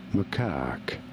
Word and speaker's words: macaque: You do want to stress on the second syllable, on the cack syllable. Macaque.